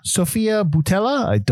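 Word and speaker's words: Sofia Boutella: Sophia Boutella. I probably said that wrong. – That's right.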